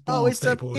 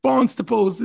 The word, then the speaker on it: Barnstaple: He's like, it's not pronounced Barnes Staple. – It's up the I too. – Barnstaple's in the up to I too.